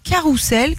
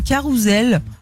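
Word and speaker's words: carrousel: Et puis on ne prononce pas le T à adéquate ou encore carousel qui se dit avec le son Z. Normalement, on dit un carousel.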